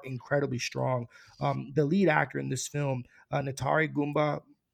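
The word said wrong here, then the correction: Ntare Guma Mbaho Mwine: well, I want to get his name right. Ntari Guma Imbaho Imwini.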